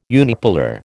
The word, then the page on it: unipolar: American English